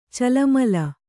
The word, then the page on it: ಚೆಲ್ಲುಮಲ್ಲಿ: cellumalli